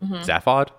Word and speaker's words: Zaphod: Okay, so I have some interesting research here on lemons, because there's a part where they put like a thinking cap on Zaphod.